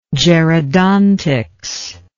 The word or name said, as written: gerodontics